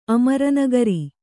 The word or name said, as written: ಅಮರನಗರಿ